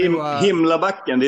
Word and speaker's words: Himlabacken: So pronounce the album title correctly for everybody. – Okay. – I can do it. Himlabacken. – There you go. – Himlabacken. – Yeah, which means Himlabacken.